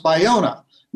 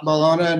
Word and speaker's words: Ballona: And if I may be so impertinent to start off and ask you to tell you that it's pronounced Ballona, not Ballona, and it's a really common mistake.